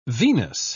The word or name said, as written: venous